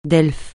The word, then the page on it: Delphes: francais